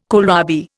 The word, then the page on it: kohlrabi: Indian